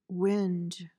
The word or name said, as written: whinge